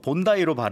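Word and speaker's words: Bondi: 크쌤, 본다의 비치예요. 본다이로 발음해 주세요. 호주인들은 웁니다.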